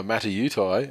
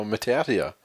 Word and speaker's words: Mata'utia: Brothers Sione and Chanel Matautia, let's say, while we're reading the article, scored 5 tries between them, but on radio they were Matautai and on TV they were Matautia. That's because radio's Wendell Saylor asked their brother, St. George Illawarra's Peter Matautia, how to pronounce it. He went for Matautai. But when a set of 6 asked Channel his preferred pronunciation, he replied Matautia.